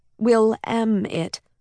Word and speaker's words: Willamette: Jenny spoke the word in her head, Will-am-it, a pronunciation people said didn't match with its spelling.